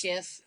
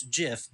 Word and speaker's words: GIF: Not That's, GIF. – Is uh, that what you're? Jesus Christ! Okay. Yeah. – All right. – Eh, it's graphic interchange format, so it's a hard G mm, on graphic.